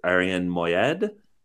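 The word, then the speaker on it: Moayed: I'm seeing her and my beloved, I believe his name is pronounced Arian Moyed, who plays Stewie on Succession, is in the play.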